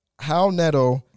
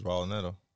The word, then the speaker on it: Raul Neto: Do you not know that's how you pronounce his name? – I didn't. I thought it was Raul Neto. – Hal Neto. H is Hal Neto by the pronunciation.